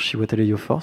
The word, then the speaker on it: Chiwetel Ejiofor: et il y a aussi, alors, Chiwetel Ejiofor, si je dis pas de bêtises, si je prononce son nom correctement.